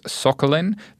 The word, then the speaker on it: Sokolin: I'm your host, Simon Taylor, and I'm joined by the returning Lex Sokolin, the global fintech co-head at Consensus.